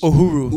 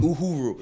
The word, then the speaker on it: Uhuru: Uhuru. – Uhuru. – Yeah. – So for the listeners, explain like so the premise Uhuru, of the show, where you can find it. – Beautiful. Uhuru.